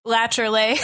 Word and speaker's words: Appalachia: Whether it's Appalachia or Appalachia.